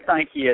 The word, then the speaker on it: Nike: Oregon Ducks are a brand now with Nike, so it is Nike, right? I hope I'm not saying that wrong. – Yeah, it's Nike, and their facilities are rival anyone's.